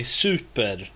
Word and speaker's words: super: Det är fel, super, det var fel betoning på ordet, det är super.